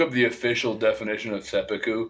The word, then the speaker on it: seppuku: I don't know if I'm saying it right. Seppuku is that officiation? Yeah, it's seppuku.